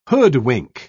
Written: hoodwink

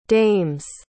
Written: dames

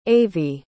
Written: avie

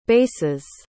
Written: basses

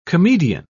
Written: comedian

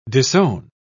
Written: disown

